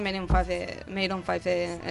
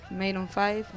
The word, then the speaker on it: Maroon 5: No me sorprende que esté Maroon 5, en la lista. – Está claro sí. Que sobra, ¿no? – ¿Cómo se pronuncia? ¿Gine? – Maroon 5.